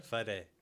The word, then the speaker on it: Fede: Is it Fede? – Fede.